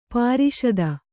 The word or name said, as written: ಪ್ರಸ್ತ